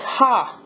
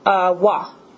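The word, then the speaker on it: wa (は): This character right here that I'm writing is pronounced ha, normally. – Okay. – But when you use it in a sentence to, like, I guess, modify a word, like, it tells you what that word's doing. That subject's doing. It's pronounced wa.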